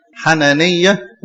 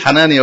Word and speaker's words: حنانية: حنانية، وامرأته اسمها ايه؟ سفيرة. احنا يعني بننطقهم غلط، يعني احنا بنقول حنانية وسفيرة، بس هو في الحقيقة ده النطق الصح، يعني حنانية وسفيرة.